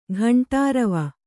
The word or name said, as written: ಘಂಟಾರವೆ